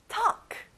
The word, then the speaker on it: talk: 자, L 발음은 안 나요? talk.